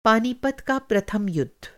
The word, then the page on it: पानीपत: Hindi